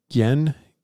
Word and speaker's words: Ghen: Is that how we say his name? Yeah, Gien, G-H-E-N, the barbarian.